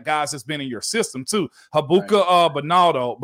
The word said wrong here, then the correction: Baldonado: but how do you say it? Baldonado. – You've been saying it better right there than I've been saying it all week.